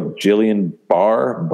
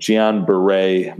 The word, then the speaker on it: Guillain-Barré: Frederick got diagnosed with, looks like it's spelled like Guillain-Barré, but Guillain-Barré, my doctor friends are telling me is the appropriate pronunciation.